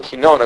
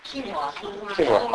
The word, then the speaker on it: quinoa: Quinoa. – I call it quinona because I don't know how to say it. All right. Quinoa? – Quinoa.